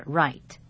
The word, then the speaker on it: write: 英文里有好几个字的发音都是 right，比如说写字的写就是 right，但是它的拼法是 W-R-I-T-E，而不是 R-I-G-H-T。我们今天要讲的 right R-I-G-H-T 可以解释为左右的右，或者是正确的，也就是对的。